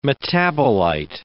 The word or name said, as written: metabolite